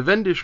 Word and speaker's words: Wendish: The Wendish Crusade is spelled with that most pesky of items, the German W, which is pronounced with a V sound.